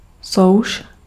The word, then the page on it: souš: /sɔʊ̯ʃ/